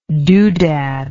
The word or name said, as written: dodad